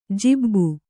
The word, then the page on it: ಜಿಬ್ಬು: jibbu